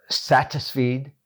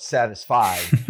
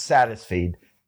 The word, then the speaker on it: Satisfeed: Our website is Satisfeed. And believe me, spell check will want you to spell it Satisfied, but it's Satisfeed. S-A-T-I-S, F as in Frank, E-E, D as in David, .org.